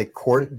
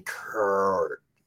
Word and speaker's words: court: I was actually trying to say court, but according to my roommate, I've been doing weird things with my speech patterns lately. – Huh. – What's that mean? – I've been mispronouncing my O's. I was saying Kurt.